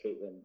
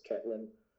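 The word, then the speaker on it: Ketlen: I think it's pronounced Caitlin, but it's Ketlin.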